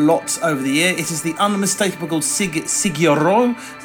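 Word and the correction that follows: Sigur Rós: That's not. See, I've, uh, what's wrong? I, have pronounced this so many times. It's Sigiros. with a with a couple of very very beautiful records.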